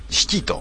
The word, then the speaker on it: 屍鬼: 岡室さんっていうのがなんか来るらしいですよ、なんか、で自己責任系で、お前が呼んだんだからお前が処理しろっていうこと、うん、で 207 番シキと、屍鬼と書いてシキと読むらしいですね、岡室さんっていうのがあるんですね。